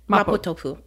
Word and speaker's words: mapo tofu: Am I saying that right? – 100%. Mapo tofu.